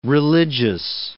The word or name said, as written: religious